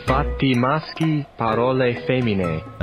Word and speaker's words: Fatti maschi, parole femmine: Fatti maschi, parole femmine.